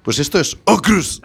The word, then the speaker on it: Aukrust: Yo visualizo claramente que es Aukrust. – Pues, ¿por qué no? – Pronunciado así, tal cual. – Aukrust.